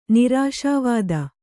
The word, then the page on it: ನಿರಾಶಾವಾದ: nirāśā vāda